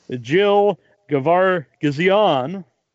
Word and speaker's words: Gevargizian: And we're joined by writer, director, editor, and producer, Jill Gevargizian.